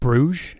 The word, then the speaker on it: Bruges: Is it just Bruges? Is that how you pronounce it? – Yeah.